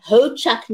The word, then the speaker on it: Ho-Chunk: So Lorena is a proud, yes, Lorena is a proud tribal member of the great Ho-Chunk Nation. And am I saying that correctly, Lorena? – Yes, you are.